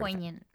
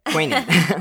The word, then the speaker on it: poignant: Along with many other, like, how do I pronounce that? I don't know why I use that word. – Poignant. – That's what I thought. Okay, perfect. Yes, that's right. I've been reading this word for decades and don't know how to say it.